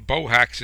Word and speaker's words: Bohacz: my name Bohacz is very easy to recognize. Most people can't pronounce it. It's like a bow and a hacksaw, but it's easily recognized in the byline of a magazine article.